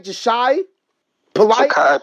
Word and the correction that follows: Ja'Kai Polite: Ja'Kai Polite. – Oh, I'm sorry. Thanks for the correction, Mad Mike.